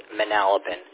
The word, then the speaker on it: Manalapan: Yeah, you nailed it. The last go-round, your pronunciation was on. It's Manalapan. It's a tongue twister.